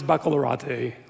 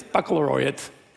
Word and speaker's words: baccalaureate: 27 years ago, I know it's baccalaureate, whatever, that's how it's pronounced. 27 years, it's baccalaureate.